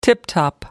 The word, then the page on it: tiptop: British English